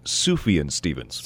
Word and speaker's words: Sufjan Stevens: And the correct pronunciation is Sufjan Stevens.